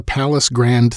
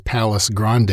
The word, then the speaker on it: Palace Grand: She quickly nailed down a high-paying gig at the Palace Grand Theater, which I was kind of ironically mispronouncing as Palace Grande in that fake vaudeville script that I opened us up with.